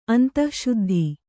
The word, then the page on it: ಅಂತಶ್ಶುದ್ಧಿ: antaśśuddhi